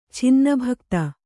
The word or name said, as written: ಛಿನ್ನಭಕ್ತ